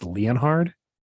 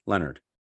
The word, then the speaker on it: Leonhard: I think it's Leonhard. Is that how you say it? – No, no, no, no. Brian Leonard. Leonard.